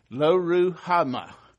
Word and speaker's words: Lo-ruhamah: Lo-Ruhama.